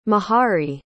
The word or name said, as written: mahari